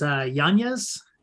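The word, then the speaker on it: Yañez: Yeah, so my last name is Yanez. It's there's like an Enya in it, so like a tilde over the N.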